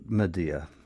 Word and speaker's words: Medea: Well, what if I said here in the back, this wonderful edition, I pronounce it Medea.